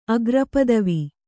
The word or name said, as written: ಅಗ್ರಪದವಿ